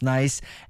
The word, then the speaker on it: Nyce: » Il y a une fille qui m'a écrit, elle s'appelle Annick Côté et je crois que son nom d'artiste se prononce Nice, N-Y-C-E.